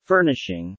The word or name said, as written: furnishing